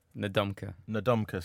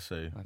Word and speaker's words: Ndamukong Suh: Ndamukong Suh.